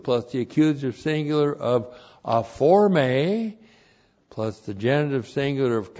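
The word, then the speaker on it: aphorme: A-P-H-O-R-M, long E, aforme.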